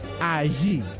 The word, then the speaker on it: Ayew: Semenyo será uno de los delanteros y el delantero estrella de la selección de Ghana es Ayew. Allí, listo, allí, allí, así se pronuncia,